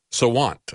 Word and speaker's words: Sawant: Her name is Sawant, I think, is the way you pronounce her name.